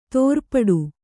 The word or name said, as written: ತಿರುಪಾಟ್ಟು